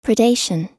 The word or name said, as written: predation